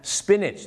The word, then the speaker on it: spinach: spinach